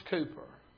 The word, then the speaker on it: Cowper: Last name spelled C-O-W-P-E-R. But it's pronounced Cooper.